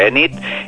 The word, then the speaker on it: Enid: Fixem-nos en el nom, Enid, és el que tothom es demana d'entrada, com ho hem de pronunciar. Sembla que s'ha de pronunciar Enid, i aquest nom Enid és d'origen galès.